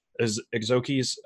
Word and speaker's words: Exookzi: We've had Loroseco as a guest on the show before, but just assuming from nothing, let's start with Exookzi's. I always fuck up the pronouncing of this name.